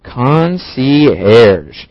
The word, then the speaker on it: concierge: Concierge. C-O-N-C-I-E-R-G-E. Concierge. – 下一个单词 concierge. Well, this is a French word.